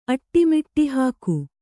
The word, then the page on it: ಅಟ್ಟಿಮಿಟ್ಟಿಹಾಕು: kannada